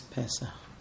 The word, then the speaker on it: Pesach: According to the difference between the three dots meaning E and two dots meaning A, what is the correct pronunciation of the word Pesach? Pesach or Pesach? – Usually it's Pesach.